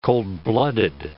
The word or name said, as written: cold-blooded